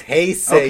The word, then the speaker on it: Heisei: It's Heisei.